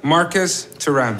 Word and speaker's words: Marcus Thuram: Marcus Thuram.